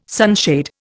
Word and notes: sunshade: Indian

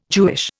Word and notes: Jewish: Indian

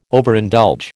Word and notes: Overindulge: American English

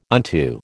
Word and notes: unto: American English

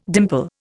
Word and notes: dimple: Indian

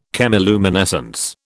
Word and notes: chemiluminescence: British English

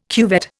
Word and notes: cuvette: Indian

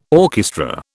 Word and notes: orchestra: British English

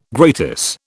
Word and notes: gratis: British English

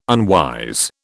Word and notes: unwise: British English

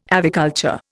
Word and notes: aviculture: Indian

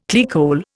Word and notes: clearcole: Indian

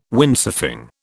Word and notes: Windsurfing: British English

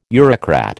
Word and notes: Eurocrat: American English

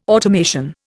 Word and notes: automation: Indian

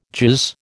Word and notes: jizz: American English

